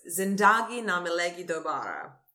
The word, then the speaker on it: Zindagi Na Milegi Dobara: And it is a coming-of-age film called, and I'm going to butcher this, Zindagi Na Milegi Dobara.